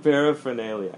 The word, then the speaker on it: paraphernalia: it's E-R. It's E-R, but we usually pronounce it like paraphernalia. But I know if you look at the spelling, it looks like it's paraphernalia. But people usually say paraphernalia like that.